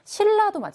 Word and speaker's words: 신라: 신라도 마찬가지죠. 신라 발음하면은 받침은 원래는 니은이지만 발음을 하면 리을로 바뀌게 되죠.